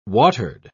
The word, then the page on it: watered: British English